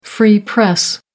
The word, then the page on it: free press: American English